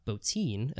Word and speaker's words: Bottin: But Rob Bottin, as I've been told is how you pronounce his name, did the design and effects.